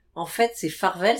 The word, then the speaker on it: Farvel: Donc c'est pas le cap Farvel. c'est le cap adieu. C'est le cap adieu, c'est le cap farvel. – F A R V E L. – Ouais, comme ça se prononce.